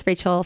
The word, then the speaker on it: Rachael: Rachael Edwards, Rachael spelled like Michael Jackson, RACHAEL.